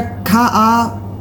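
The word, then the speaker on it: Kafvka: Du nimmst Alle hassen Nazis von, ich weiß nicht, wie ich es richtig ausspreche, ist es Kafka oder Kafka. – Aber mit V oder in der Mitte, ne? – Mit V, genau.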